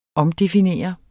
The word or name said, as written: omdefinere